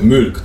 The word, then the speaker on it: Mulk: To nie jest Milk, raczej się nie wymawia Milk. Tak. Milk. – Czyli Mulk, tak? Takim, Mulk. Z takim umlautem trochę.